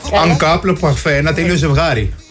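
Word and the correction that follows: en couple parfait: Αν κούπλε παρφέ, να το πούμε σωστή προφορά.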